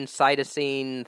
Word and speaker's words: cytosine: Is it adenine, cytosine, thymine, and guanine? – Right.